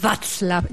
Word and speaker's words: Václav: Bueno, lo fundó un señor que se llama Václav, ya sé hablar checo. – Muy bien, ya me di cuenta. Muy buena pronunciación la tuya.